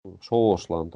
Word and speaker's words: Шоушлан: И вместо шошлан Шоушлан, там О, Шоушлан, да? Слышно хорошо. Не, не какая-то другая буква, а именно О, Шоушлан. А, а поскольку ударение падает на А, вот это О редуцируется. И слышно непонятно что. Что-то там. Шошлан. Что там? О, А, Е и... Нет, какой-то редуцированный звук типа Шашлан, шашлан, шашлан, что там, а, о, непонятно.